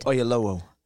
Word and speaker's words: Oyelowo: Oyelowo. – Oyelowo was my second choice.